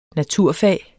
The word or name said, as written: naturfag